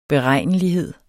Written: beregnelighed

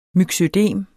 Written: myxødem

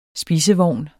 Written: SPISEVOGN

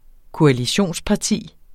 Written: koalitionsparti

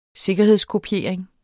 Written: sikkerhedskopiering

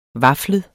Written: vaflet

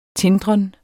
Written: tindren

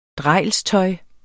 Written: drejlstøj

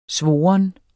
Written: SVORNE

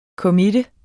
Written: committe

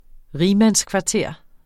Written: rigmandskvarter

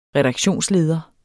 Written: redaktionsleder

